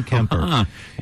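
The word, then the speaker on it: Kuemper: Congratulations, you didn't pronounce the U in it. Darcy Kemper.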